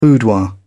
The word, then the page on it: boudoir: British English